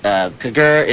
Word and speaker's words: cogar: Kagur is the Irish word for whisper. It looks like C-O-G-A-R, but it's pronounced Kagur. It's Irish Celtic. And Cecile, which is Brian's magazine, is S-I-S-I-A-L.